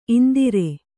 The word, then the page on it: ಇಂದರ: indara